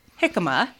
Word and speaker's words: jicama: because it starts with a J, but it is pronounced jicama.